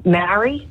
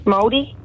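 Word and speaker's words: Māori: Maori? Maori? – Oh. And you pronounced it beautifully.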